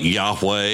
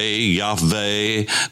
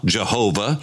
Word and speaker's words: Yahweh: We call it Yahweh, Yahweh, depending upon your pronunciation. We know it's not Yahuwah, Jehovah, because we know that that is taking the vowels of Adonai, Iowah, and putting those, placing those under those four letters, and that gives you Yahuwah, Jehovah. And we know that's not the pronunciation of God's name for sure.